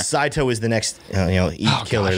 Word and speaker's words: Saito: We got one from Monica T saying, okay, so this coin is pronounced Saito. So S-A-I-T-O, Saito.